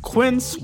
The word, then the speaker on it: Quince: Today Explained's support today comes from Quince, which rhymes with since but is spelt with a Q-U.